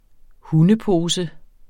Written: hundepose

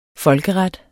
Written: folkeret

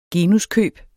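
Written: genuskøb

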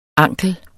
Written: ankel

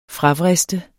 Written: fravriste

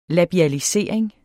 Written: labialisering

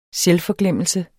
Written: selvforglemmelse

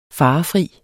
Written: farefri

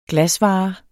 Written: glasvare

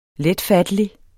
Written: letfattelig

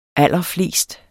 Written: allerflest

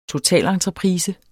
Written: TOTALENTREPRISE

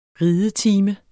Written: ridetime

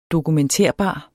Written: dokumenterbar

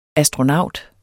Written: ASTRONAUTEN

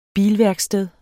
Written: bilværksted

